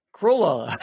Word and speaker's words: Crayola: Crayola. I had to really try on that. Crayola. Crayola. Yeah. It's like payola, but with a cray.